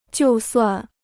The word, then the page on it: 就算: jiù suàn